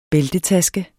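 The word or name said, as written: BÆLTETASKE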